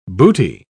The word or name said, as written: booty